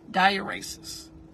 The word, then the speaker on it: dieresis: Diuresis. And that is spelled D-I-E-R-E-S-I-S. Because remember, it's Latin and Greek. So the E is like an A. Diuresis. D-I-E-R-E-S-I-S.